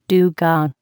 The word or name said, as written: dugong